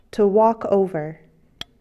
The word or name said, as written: walk over